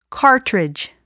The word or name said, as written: cartridge